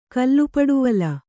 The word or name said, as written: ಕಲ್ಲುಪಡುವಲ